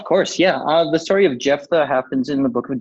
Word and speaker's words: Jephthah: Yep, Jephthah.